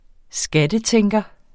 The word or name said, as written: skattetænker